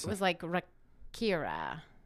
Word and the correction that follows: Rakija: I said it wrong. It is Rakija. – Rakija.